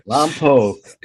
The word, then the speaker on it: Lompoc: Lompoc.